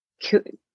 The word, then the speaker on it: kueh: Desserts here are usually called kue. It's spelled K-U-E-H, like cake.